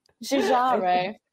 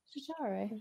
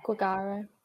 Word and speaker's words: Giugiaro: Giugiaro. – That's right, isn't it? I would say that. – Giugiaro. I said Gugaro, but I don't know.